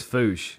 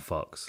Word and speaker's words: Fuchs: How do you say it? – The it's Fouche. – Fouche lubricant, yeah. Right? – F-U-C-H-S. – Which are now part it's of... – definitely not Fox.